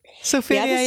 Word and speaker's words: Sylveria Jacobs: Aan Sylveria Jacobs. Zeg ik dat goed? – Sylveria Jacobs. Ja, dat zeg je goed.